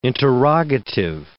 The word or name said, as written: interrogative